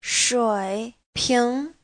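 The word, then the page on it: shuiping: shui3ping2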